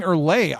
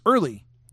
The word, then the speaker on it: Early: Because I've been saying Erlay. I thought it was Erlay. So it's early. Like early to dinner?